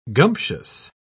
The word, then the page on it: gumptious: British English